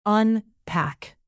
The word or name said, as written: unpack